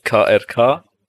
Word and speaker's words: Krk: Und da waren wir jetzt Anfang des Jahres in Kroatien auf einer Insel, die heißt KRK. – Das spricht man wie aus? – Also so, KRK, also Krk.